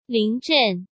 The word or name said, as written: Ling Zhen